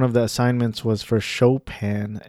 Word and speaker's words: Chopin: And it's pronounced Chopin. And I almost pronounced it Chopin earlier in this episode.